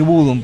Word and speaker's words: Bodom: Caso Children of Bodom, me acostumbré a decir Bodom porque me enteré que se dice así, y también In Flames, por ejemplo.